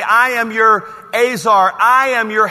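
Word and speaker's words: Azar (עזר): E-Z-E-R is the way it's spelled, but it's pronounced Azar.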